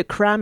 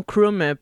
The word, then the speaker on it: Cram: C'était la pièce épicée de Lunch Crew, Crew qui s'écrit K-R-E-W et il s'agit de Cram et non Crum, petite, euh, petite euh, erreur de ma part avant d'écouter la pièce.